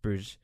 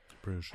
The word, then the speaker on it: Bruges: Or Bruges? Is it pronounced? Bruges. – Bruges.